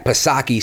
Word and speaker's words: Psaki: So kudos to Steve Doocy for asking Jen Pacircleback Psaki, silent P, of course, that poignant question.